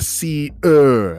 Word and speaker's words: Seer: Like burning a steak on a grill? – No, like the Seer, as in he who sees. – The Seer, huh? – Say it smoother, like one syllable, like the Seer. The Seer.